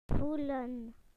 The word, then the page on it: to feel: British English